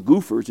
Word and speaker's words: Gophers: And Madden, who'd been a gang leader of a gang called the Goofers, it's spelled Gophers, in Hell's Kitchen, simply hired his former cop busters, as he called them, to take care of these guys.